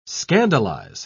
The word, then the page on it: scandalise: British English